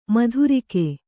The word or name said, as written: ಮಧುರಿಕೆ